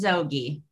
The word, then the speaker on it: Zoghi: My last name is Zoghi.